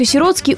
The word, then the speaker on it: сиротский: В таких словах, как «сиротка», «сироточка», «сиротский» ударение также остается на «о». Многие люди, желая оказаться более жалостливыми, ставят ударение на первый слог. Повторюсь, это в корне неверно.